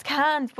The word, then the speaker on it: Cairns: So we done Cairns. – Cairns. – Yeah, apparently it's Cairns, but like... – They just don't pronounce the R.